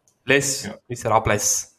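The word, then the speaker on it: Les Misérables: Vom 2000, haben sie jetzt schön ausgesprochen? Le Miserables. – Le Miserables. Les Miserables. – Genau, so heisst der Titel.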